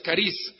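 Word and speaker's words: caris (χάρις): O se escribe charis, pero es caris.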